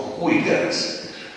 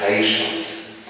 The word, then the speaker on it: Huygens: Więc wszyscy nauczyciele, niekiedy ku radości, wymawiają nazwisko wynalazcy mikroskopu Huygens, podczas kiedy prawie było to jest Heischens.